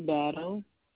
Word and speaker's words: Battle: Brittany Battle. – Battle? – Yes. – It's B-A-T-T-L-E? – Yes.